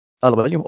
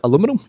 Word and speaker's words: aluminium: al- aluminium, or as Americans say, al- what was it aluminum? You, you call it aluminum.